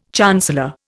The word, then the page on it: chancellor: Indian